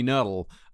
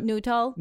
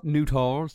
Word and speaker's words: Nuttall: You're probably not like Tony Nuttall. Uh, it's like Newtall. Newtall.